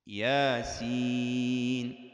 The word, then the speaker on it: يس: عندما نقرأها لا ننطق الهمزة، نقول ها يا طا را، يعني كلمة ياسين كيف نقرأها؟ نقول ياسين.